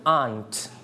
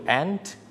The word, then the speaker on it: aunt: Or we can pronounce this word aunt. เราสามารถออกเสียงได้สองแบบคือ aunt และ aunt นั่นเองนะครับ So, this word can pronounce in two ways. Okay, you can pronounce aunt. Or aunt is okay. If you pronounce aunt, that means you use American style. But if you pronounce aunt, you use the British style.